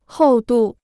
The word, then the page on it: 厚度: hòu dù